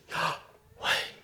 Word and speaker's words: Yahweh: Yahweh is supposed to be spoken like this. Yahweh. You breathe in on the Yah, out on the way. Yahweh, Yahweh.